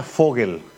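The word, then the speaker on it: Vogel: Este siempre se pronuncia Fogel. O Fogel. – Fogel. – Sí, Fogel o Fogel. ¿Por qué? Porque la V en alemán, von Prieto.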